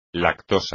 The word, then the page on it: lactosa: Spanish